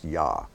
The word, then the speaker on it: Yah: Now, many times in the scripture, instead of God's full name spelled out Yod-Heh-Vav-Heh, we find His name simply spelled Yod-Heh, which we know is pronounced Yah.